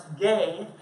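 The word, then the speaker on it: gay (γῆ): And then there's another word which is pronounced gay, G-E, and gay refers to the land of Israel.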